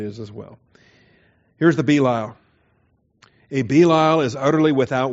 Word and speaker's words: Belial: So if you want to pronounce it Belial, have fun. Pronounce it Belial. Put the accent on the ya.